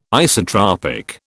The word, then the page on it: isotropic: British English